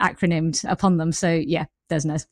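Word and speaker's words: DESNZ: So is that the correct pronunciation now? Desnes. We've been arguing internally about what it is. Desnes seems to have won.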